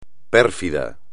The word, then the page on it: pérfido: espagnol